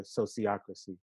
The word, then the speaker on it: sociocracy: So think of sociology and democracy, and put those together, sociocracy.